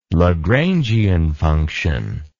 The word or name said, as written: Lagrangian function